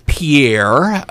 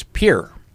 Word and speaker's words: Pierre: By the way, how is it, David, after 2 years in the capital of South Dakota, which looks like it would be pronounced Pierre, but if you're from South Dakota, David was telling me it's Pierre.